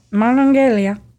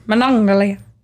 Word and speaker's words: Monongalia: And if we mispronounced that first one. – Monongalia. – Sounds Italian. – Monongalia.